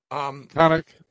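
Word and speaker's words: Konnech: This Kone, how do you say it? Konek or Kone? – Konek.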